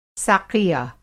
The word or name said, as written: साक़िया